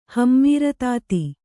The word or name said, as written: ಹಮ್ಮೀರತಾತಿ